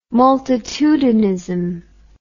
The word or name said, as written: multitudinism